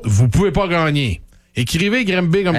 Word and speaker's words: Granby: G-R-A-N-B-Y. – D'ailleurs, y a-tu quelqu'un qui veut m'expliquer pourquoi on prononce pas ça Grimbé? – C'est parce que ça s'appelle Grimbé.